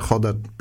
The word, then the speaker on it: Roda: Choda pisze się Roda i to znaczy dosłownie krąg.